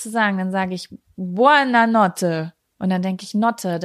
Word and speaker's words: Buona notte: Ich möchte jetzt hier an der Stelle Buona Notte sagen.